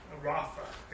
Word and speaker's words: Rapha: Jehovah Rapha is how it's pronounced in Hebrew.